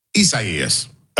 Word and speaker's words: Isaias: But do you say Isaias or Isaias? – Isaias. – All right, Isaias.